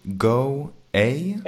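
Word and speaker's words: Go_A: Go A? – A, yeah. Yeah, it's go A. Underscore A.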